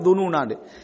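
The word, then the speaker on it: துனூனா (الظنونا): வதுன்னூன பில்லாஹி துனூனாண்டு சேர்த்து ஓதினா அந்த துனூனாண்டு நிறுத்தக்கூடாது, அந்த அலிஃப் இல்லாம உச்சரிச்சுட்டு போயிடணும். சேர்க்காம போதுனா துனூனா என்ன செய்யணும், நிறுத்தணும்.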